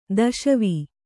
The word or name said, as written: ದಸವೆ